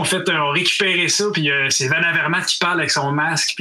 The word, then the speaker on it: Van Avermaet: Hey, Whitey, uh, I heard you have some trouble with pronouncing my name. It's Greg Van Avermaet. Van Avermaat. Van Avermaat.